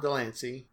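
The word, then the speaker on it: de Lancie: The card shows John DeLancey, right? I said that right? – Yes. Okay, yeah, you got it.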